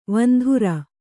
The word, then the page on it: ವಂದರಿ: Kannada